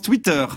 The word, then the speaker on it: Twitter: Hop, hop, hop, hop, ça se prononce Twitter, Madame Vanhoenacker.